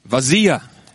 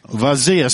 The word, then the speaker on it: vazia: Vazia. – Vazia. Spell it. – V-A-Z-I-A.